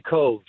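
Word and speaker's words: Cobh: And for anybody who really has family or roots in Ireland, anytime you mention, is it, am I saying that correctly, the Cobb Heritage Center? – It's actually Cove. – Cove. – Like a Lake Cove, yeah. – Like Siobhan, okay. – Because, yeah, like Siobhan, exactly.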